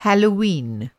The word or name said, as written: हैलोवीन